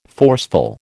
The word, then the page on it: forceful: American English